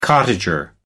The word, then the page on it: cottager: American English